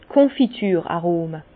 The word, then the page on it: d' Konfitür-Arome: Bas Rhin; Herrlisheim